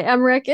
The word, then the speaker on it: Emmerich: That's all right, Emmerich.